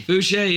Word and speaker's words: Fooshee: Everything is at, you know, whether it's Facebook or Twitter, Tom Fooshee. It's TOMFOOSHEE, two O's, two E's. That's the name they gave me. – So it's pronounced Fooshee. – Fooshee, yes, sir.